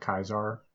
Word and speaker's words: Caesar: Julius Caesar, I think I'm pronouncing that right. – Isn't it technically pronounced like Kaiser? – Shut up.